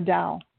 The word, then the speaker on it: Tao: And then the last card is the Tao, or it's pronounced sometimes as the Tao.